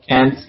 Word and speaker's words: Cairns: Yeah, so we're gonna start in Sydney, and then we're gonna hit uh, Brisbane, uh, and I'm gonna pronounce it wrong. Carnes or Carnes? Well, by the way, uh, uh, Great Barrier Yeah, Cairns, yeah.